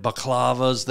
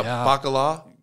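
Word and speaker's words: baklava: Tzatziki, and I'd have it out with a nice Christmas tzatziki and some homemade baklavas there. – Wow, bakala? – Well, if you want to say it wrong.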